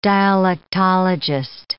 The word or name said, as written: dialectologist